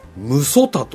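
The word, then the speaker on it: 六十谷: これはですね、無祖田と読むんですね。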